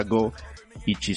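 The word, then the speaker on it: Goichi: Mm, eso es porque Suda es el apellido, pero Go 51 se pronunciaría en japonés literal 50 Go Ichi sería uno. Entonces, correcto.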